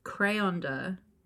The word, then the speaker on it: Krayonder: His name is Crayonder. – Right. – But it's spelled K-R-A-Y-O-N-D-E-R.